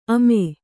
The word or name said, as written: ಅಮೆ